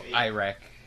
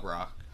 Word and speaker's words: Iraq: No, Iraq. That's how it's pronounced. Not rock.